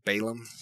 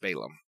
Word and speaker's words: Balaam: So, first off, Balaam, it's actually Balaam. He's one of the big gods of that time. It's just, that's the way it's pronounced, Balaam.